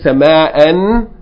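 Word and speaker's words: sama'a (سماء): Yeah, and if you keep reading, you're going to say sama'an, but if you stop on it, you're going to say sama'a, as though there's an alif there.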